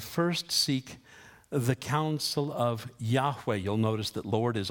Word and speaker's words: Yahweh: How do you pronounce that? It doesn't have any vowels. Wasn't a problem back then before vowels were invented. They called him Yahweh.